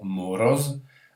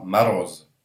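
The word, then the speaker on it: marose (мороз): Donc c'est le gel que j'ai choisi ici, avec le mot MOROZ, ce qui nous donne normalement morose, mais en russe on prononcera marose, marose. Voilà, tout simplement parce que l'accent est sur la deuxième syllabe, donc on prononce un peu moins le O tel qu'il est, mais un peu plus A.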